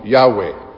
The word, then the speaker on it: Yahweh: Y mucho luego los hebreos escribían su nombre como YHWH. Y lo pronunciamos hoy día como Yahweh.